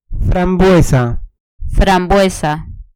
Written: frambuesa

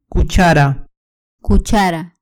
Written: cuchara

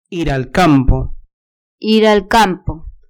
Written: ir al campo

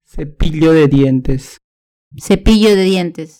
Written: cepillo de dientes

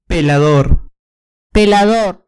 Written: pelador

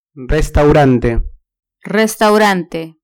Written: restaurante

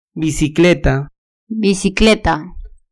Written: bicicleta

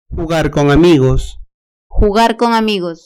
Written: jugar con amigos

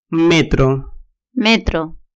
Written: metro

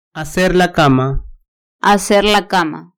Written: hacer la cama

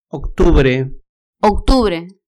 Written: octubre

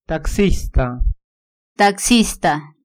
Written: taxista

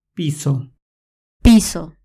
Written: piso

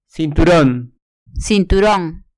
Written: cinturon

